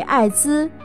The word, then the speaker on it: 艾滋: 由于该病能使人体免疫功能几乎失去作用，所以被命名为获得性综合免疫缺乏症，英文缩写为 AIDS，汉语读音为艾滋。